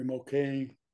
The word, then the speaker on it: Moquin: I was on one of the first two or three matches, and with the old pro Larry Moquin, it's spelled M-O-Q-U-I-N.